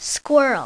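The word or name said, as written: squirrel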